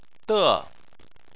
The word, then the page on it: de: 4